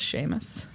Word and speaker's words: Seamus: Pronounced Seamus.